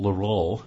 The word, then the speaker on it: Leroux: It was written by a Frenchman, Gaston Leroux. Am I pronouncing his name right? – Close enough, yeah. Yeah. – You know, you have to get that thing going in the back of the throat.